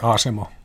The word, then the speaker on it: Asemo: Ilmarinen on itse muuttunut asemoksi, tai asemo, miten se lausutaan. – Mm, asemo.